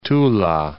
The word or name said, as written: Tula